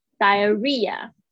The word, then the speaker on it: diarrhea: 然后 R R H E A diarrhea，注意一下 H 是没有发音的哦，R 要重复，H 没有发音，所以有点难记。